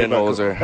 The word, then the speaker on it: Budenholzer: Speaking of professionals, professional basketball coach Mike Budenholzer. I doubt I pronounced that correctly. – Budenholzer. – That's Budden, see, like yeah. – Joe Budden? – Yeah.